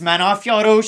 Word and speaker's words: Manon Fiorot: It's pronounced Manon Fiorot.